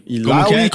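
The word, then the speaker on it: Illaoi: Ah, mudou um pouco a pronúncia, Mudou um pouquinho, Tem a Iloa, Ilawa? – Ilaui. – Como é que é? – Ilaui. – Ah, esse tá bom.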